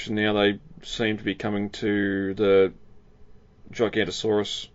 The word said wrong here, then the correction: Giganotosaurus: How do you say it? – Giganotosaurus? – That's the one, yeah.